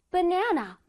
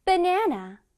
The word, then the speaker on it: banana: ba 부분에서는 약하게. b, b, b. 오케이. na 부분에서는 강하게. 중간에 na 빨간색 부분 보이지? 거긴 강하게 발음을 해줘야 돼. banana. 그 다음에 마지막 네는 또 약하게. 약하게, 강하게, 약하게.